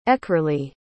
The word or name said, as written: aekerley